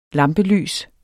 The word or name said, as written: lampelys